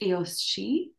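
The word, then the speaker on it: Aos Sí: So the race of the fairy people, which is pronounced Eos She,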